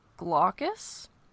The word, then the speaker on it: Glaucus: So that, in Greek mythology, his name is Glaucus?